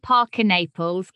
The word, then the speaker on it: Parker-Naples: People hear Parker-Naples, get a bit bored of the double-barreled thing, think it sounds a bit like Parker Bowles.